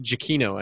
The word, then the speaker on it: Giacchino: I think I looked up how to pronounce his last name, and he says it Giacchino.